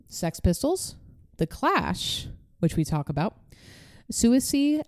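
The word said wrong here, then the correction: Siouxsie: and the Banshees, Buzzcocks. – Susie and the Banshees. – Susie, thank you.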